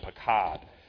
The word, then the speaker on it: paqad: Now, this Hebrew word that's translated took note is spelled P-A-Q-A-D, pakad.